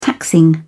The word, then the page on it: taxing: British English